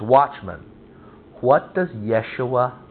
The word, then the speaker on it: Yeshua: Yahweh saves is the meaning of the name Jesus, Yeshua, the Hebrew pronunciation of Jesus.